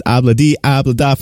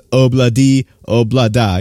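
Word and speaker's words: Ob-La-Di, Ob-La-Da: they swear up and down, and they're probably correct that it's pronounced Obla D, Obla Da. You know, considering that's how the Beatles said it, that's probably the correct way of saying it, but just rolls abla di abla.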